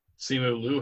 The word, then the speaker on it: Simu Liu: Simu Liu had a whole different point, too, that he was putting out there about it.